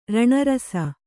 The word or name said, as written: ರಣರಸ